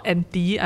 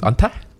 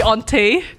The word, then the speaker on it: Ante: So what happened was that the guy who's called Anty, I hope I'm pronouncing his name correctly. – No, it's Anta. – maybe Ante, but yeah, anyway, A-N-T-E.